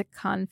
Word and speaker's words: Cannes: It won all kinds of awards at the Cannes Film Festival. Did I say that correctly, Ken's? – You did, yes.